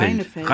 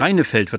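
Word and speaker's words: Rijneveld: Das war Mareike Lukas Rinfeld. – Reinefeld. Reinefeld wird das ausgesprochen.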